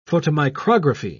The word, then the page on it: photomicrography: British English